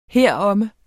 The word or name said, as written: heromme